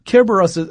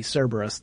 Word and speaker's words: Kerberos: Kerberos, that's the Greek pronunciation for, we often will make it a soft C, Cerberus, the three-headed dog.